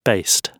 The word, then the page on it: based: British English